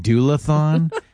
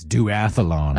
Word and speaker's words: duathlon: Until somebody came up, they're like, by the way, you keep saying doulathon, and I think it's duathlon.